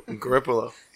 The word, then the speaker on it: Garoppolo: Garoppolo. – Yeah, Jimmy Garoppolo. Garoppolo. – Oh, I said it right.